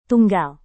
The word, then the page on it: tunggal: indonesian